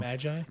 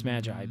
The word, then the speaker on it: Magi: Considering the same dude brought us Maggie. I don't know. – Magi. – No, that's how we pronounce it, but like I don't think that's okay. My bad. Fine, it's Magi.